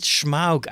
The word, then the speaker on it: Smaug: He pronounces it Smaug, I noticed in one of his videos. – That's almost cool. Sounds German. That's how I would pronounce it.